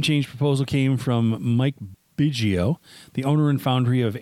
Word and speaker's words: Biggio: Fox says that Biggio's business has been a major impact on the town economy.